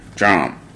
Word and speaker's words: DRAM: It's called Look Back, featuring DRAM, or what do you say, Dram? The dude who does Broccoli, pretty much. But I'm digging this track. How you say it? – Drum.